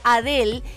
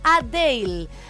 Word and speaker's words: Adele: En la parte de preguntas y respuestas, la británica de 34 años hace un paréntesis para explicar que muchas personas suelen referirse a ella como Adele y en realidad ella lo pronuncia Adele.